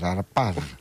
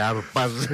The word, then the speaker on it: Αρπάζ: Και ο Γενικός Διευθυντής της Ομάδας Ισραηλινών που έφεραν το λογισμικό στην Ελλάδα, ο Μέροπ Χαρπάζ. – Μάλιστα. – Τι Χαρπάζ, Αρπάζ. – Αρπάζ. Έτσι προφέρεται, Αρπάζ.